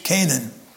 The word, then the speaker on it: Chanaan: The CH is not pronounced like the CH in our word church. Rather, it's pronounced like the CH in our word school. So, C-H-A-N-A-A-N and C-A-N-A-A-N are pronounced exactly the same way, but that's not the way you would guess in English, okay?